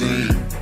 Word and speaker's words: zine: It's pronounced zine. Zine, zine, zine.